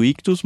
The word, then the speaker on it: Ichthus: Lembrando que Ictus se escreve I-C-H-T-H-U-S.